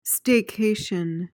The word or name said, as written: staycation